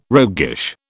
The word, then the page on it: roguish: British English